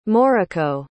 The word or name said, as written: moriko